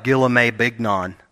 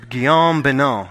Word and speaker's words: Guillaume Bignon: His name is Guillaume Bignon. That's how you say it. It looks like Guillaume Bignon, but it's Guillaume Bignon is how it's said, okay?